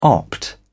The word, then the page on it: opt: British English